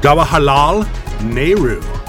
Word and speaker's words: Jawaharlal Nehru: Jawaharlal Nehru.